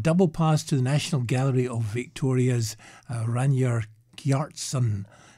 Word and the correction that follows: Ragnar Kjartansson: Ragnar Kjartansson.